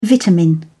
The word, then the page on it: vitamin: British English